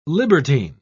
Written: libertine

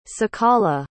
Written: ciqala

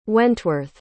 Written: wentworth

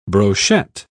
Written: brochette

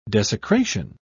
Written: desecration

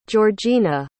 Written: georgina

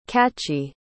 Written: cachi

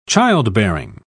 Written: childbearing

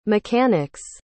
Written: mechanic's

